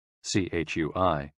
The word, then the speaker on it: Chui: CHUI, pronounced like chui, with a forceful exhale, which is also associated with the kidneys.